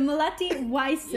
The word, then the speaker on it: Melati Wijsen: Melati Wijsen.